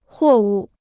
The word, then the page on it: 货物: huò wù